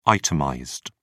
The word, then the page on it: itemized: British English